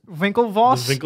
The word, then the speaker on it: Winklevoss: It's like how the Winklevoss twins. Winklevoss? – No, I think you said it right.